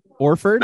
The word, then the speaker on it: Horford: Is that just like a New York thing? – Yes, that's a regional thing. You don't pronounce the H at the start, yeah. Orford. I heard. – I mean, I can't imagine the people in Worcester and whatever they're definitely Hawford. Pronouncing the H.